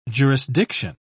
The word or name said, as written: jurisdiction